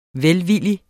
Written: VELVILLIGT

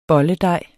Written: bolledej

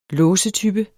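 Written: låsetype

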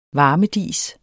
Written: varmedis

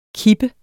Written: kippe

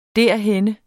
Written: derhenne

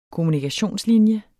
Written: kommunikationslinje

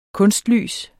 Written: kunstlys

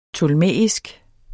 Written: ptolemæisk

